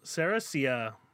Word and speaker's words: Ceresia: Ceresia, Ceresia. Oh boy. It is spelled C E R E S I A, uh, also known as Dosed on on IMDb, but if you type in Cercia, it will come up on there.